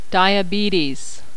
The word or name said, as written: diabetes